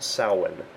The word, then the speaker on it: Samhain: It's going to look like it says Samhain. It's actually pronounced Samhain.